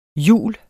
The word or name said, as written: jule